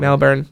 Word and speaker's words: Melbourne: I would say like Melbourne. Melbourne. – Like burn, like you're burning something. – Melbourne.